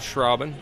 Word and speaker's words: Schrauben: I'm Phil Schraubin, chaplain at Pella Regional Health Center, S-C-H-R-A-U-B-E-N, Phil.